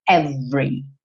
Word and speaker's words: every: A pronúncia padrão é essa segunda que aparece aqui com o ezinho do meio pintado de cinza, porque ele não vai ser pronunciado, e aí você vai ter every, every, tá? Do V vai direto pro R.